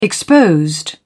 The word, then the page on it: exposed: British English